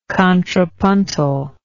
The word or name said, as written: contrapuntal